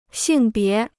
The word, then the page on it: 性别: xìng bié